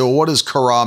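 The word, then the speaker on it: kara (χαρά): We see it's the word kara, and you can see there's the phonetic spelling so that you know how to pronounce it, kara.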